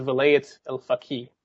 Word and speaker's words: Velayat al-Faqih: which was it's pronounced the Velayat al-Faqih.